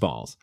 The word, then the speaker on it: Baals: Spelled B-A-A-L-S. – That's crazy. – Pronounced Balls.